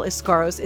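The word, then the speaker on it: Eskaros: This has been Rinse and Repeat with Carol Iscaros.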